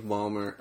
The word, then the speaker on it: Ballmer: The second part also has a nice little gif of Steve Ballmer, or is that, am I saying that right? – Yes.